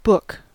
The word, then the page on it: book: American English